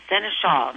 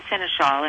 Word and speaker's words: Senechal: Seneschal. My name is Sean Seneschal, and the book name is Animal Sign to You.